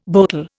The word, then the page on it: boatel: Indian